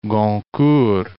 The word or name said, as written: Goncourt